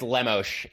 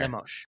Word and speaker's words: Lemos: It's I believe it's Lemos. It's, you know, it's the Brazilian pronunciation. So throw a little Portuguese heat on there. – Lemos.